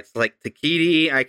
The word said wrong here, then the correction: Taika Waititi: Taika Waititi.